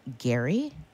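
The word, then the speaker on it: Gerry: The governor from Massachusetts, you're absolutely right, but he pronounced his name Gary.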